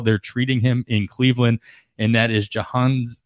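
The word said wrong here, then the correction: Jhonkensy: I actually put the pronunciation here so I wouldn't mess it up, but I still mess it up. It's Jahan Kenzie, Noel, first baseman prospect for the Cleveland Guardians.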